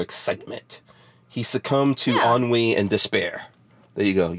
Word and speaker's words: ennui: And they had a sense of, you know, like, I can never pronounce this word, E-N-N-E-N-U-U-I, Anu, Enu, like when you basically have nothing going on and you're so bored that... – I don't even know what that is. No. – I'm like, that's a new word ever. – Wait, hold on. Spell this again. Hold on. Wait a second. Let me pull up the Google machine. – Enu. Hold on. It's E-N-N-U-I. – Ennui.